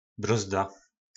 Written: brozda

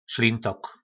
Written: slintok

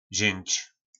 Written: zync

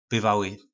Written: byvaly

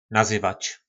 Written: nazyvac

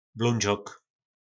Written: blondzok

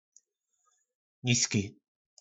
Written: nizki

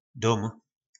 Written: Dom

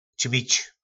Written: cmic

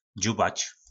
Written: dzubac

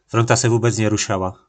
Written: Fuzbal

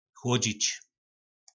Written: chlodzic

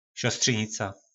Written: sostrynica

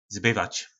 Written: zbyvac

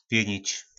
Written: pjynic

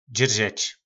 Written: dzyrzec

